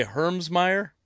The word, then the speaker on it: Hermsmeyer: You are, and you say Hermsmeyer.